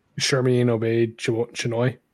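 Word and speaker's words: Sharmeen Obaid-Chinoy: Shermin Obaid Chinoy.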